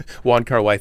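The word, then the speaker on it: Wong Kar-wai: it kind of annoyed me because I'm a Y-Car-1 fan. Why am I saying the name wrong? Juan Carwai fanboy.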